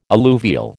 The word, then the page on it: alluvial: American English